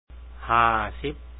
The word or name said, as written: 50